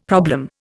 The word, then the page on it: problem: Indian